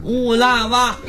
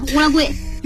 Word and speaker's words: 乌拉圭: 那个是青蛙的蛙，去掉那虫子边乌拉蛙。 – 这这这，不开玩笑，喂，咱们是强化这个名字正确发音啊，乌拉贵。